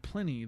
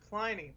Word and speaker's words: Pliny: Supplanting Pliny, the elder from California's Russian River Brewing that had claimed the top spot for the previous 8 years. What was that? – It's pronounced Pliny. Pliny the Elder.